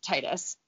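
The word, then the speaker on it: Tidus: He is Titus. It's Titus is apparently the way you're supposed to pronounce it, but I think that sounds stupid.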